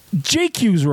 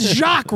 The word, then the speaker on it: Jacques: JQ's Road. What JQ's the fuck is the JQ's Road? It's Jacques Road.